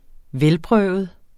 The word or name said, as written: velprøvet